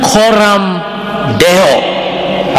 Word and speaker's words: Coram Deo: It should be done coram Deo. I'll spell it. C-O-R-A-M. Deo. D-E-O.